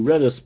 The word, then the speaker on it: Redis: Anywho, Clacke, enunciation 2, Redis pronunciation. Most people pronounce Redis, not Redis.